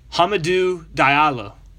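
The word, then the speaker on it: Hamidou Diallo: Is it Hamidulo Diallo? – Hamadou Diallo from Oklahoma City Thunder, and John Collins yes.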